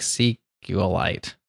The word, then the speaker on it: SQLite: Nobody knows how to pronounce it the correct way, but it's actually like meteorite. So it's like SQLite.